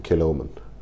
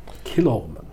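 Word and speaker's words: Kilchoman: Det staves med CH, men så vidt jeg har kunnet bringe på bane, så uttales det Kilorman. Ja. Kilorman.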